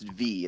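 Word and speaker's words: Viy: I watched, I believe it's pronounced V. It's V-I-Y.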